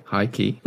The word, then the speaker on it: H1-KEY: The band is called, I think they're called High Key, but it's H1-KEY. – Oh, very interesting way to spell it. Okay, High Key.